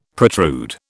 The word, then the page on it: protrude: British English